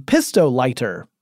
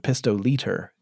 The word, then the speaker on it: pistolighter: So one of the early inventions to use ferrocerium as a way to start fires was called the pistolighter. I actually have an outtake where I said pistoliter because it's spelled like liter, but it's lighter. It's a lighter.